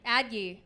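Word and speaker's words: Addyi: Adye. Like Soon-Yi.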